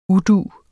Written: udu

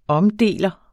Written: OMDELEREN

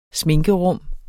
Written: sminkerum

